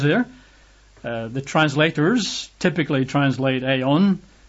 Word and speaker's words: aion: In English, that would be A-I-O-N. You might pronounce that Ion. I think biblical Greek classes tend to pronounce it as Ion, although I think those that try to get back to the original pronunciation say it's probably Aeon, A-I-O-N, or Aionios, which is just the adjective, A-I-O-N-I-O-S, Aionios.